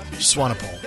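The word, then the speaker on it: Swanepoel: Swainpole.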